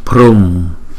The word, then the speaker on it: Prum: Chữ bờ hờ chúng ta đọc là bờ rum, bờ rum, Om Prum. Thấy không? Chữ u có dấu gạch ngang chúng ta đọc hai nhịp.